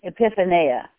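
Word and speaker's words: Epiphaneia (ἐπιφάνεια): E-P-I-P-H-A-N-E-I-A, Epiphaneia.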